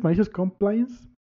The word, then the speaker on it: Malicious Compliance: Y si no son tanto de ley y les más, les gusta más el tipo sabor podcast, busquen también Malicious Compilines. ¿Cómo lo pronuncias? Malicious Compliance. – malicious Compliance. Compliance, ándale, Malicious Compliance.